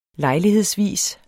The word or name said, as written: lejlighedsvis